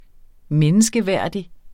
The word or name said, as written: menneskeværdig